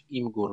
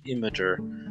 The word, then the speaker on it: Imgur: który nazywa się Imgur lub też Imager, e, w zależności od tego, kto, mm, kto to wymawia.